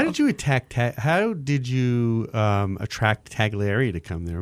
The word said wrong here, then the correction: Tagliare: So let me correct you because they corrected me. It's pronounced Tagliare.